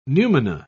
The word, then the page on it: numina: British English